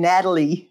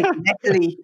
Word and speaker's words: Natalie: And I like the way Americans say my name, Natalie. We always say Natalie.